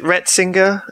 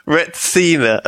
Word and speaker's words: retsina: I don't know if I pronounce Retsina correctly. Is it Retsinger? – Retsinger.